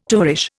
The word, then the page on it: tovarish: Indian